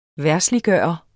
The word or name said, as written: verdsliggøre